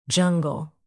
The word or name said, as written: jungle